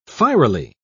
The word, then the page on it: fierily: British English